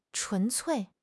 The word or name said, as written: 纯粹